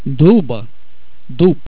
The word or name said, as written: dub